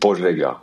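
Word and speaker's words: Požega: Okay, und beim letzten muss ich mich entschuldigen, falls ich das nicht richtig ausspreche, aber ich sag mal Stuttgart oder Pozega? – Stuttgart. – Das müsste dein Geburtsort sein, wenn ich richtig recherchiert habe. – Ja, das stimmt. Pozega heißt es.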